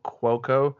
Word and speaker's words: Cuoco: But Ryan Seacrest was calling her Cuoco, K-W-O, Cuoco. So that's how it's officially pronounced, but...